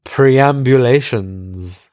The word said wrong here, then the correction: perambulations: I might have said it wrong. It's perambulations, whatever.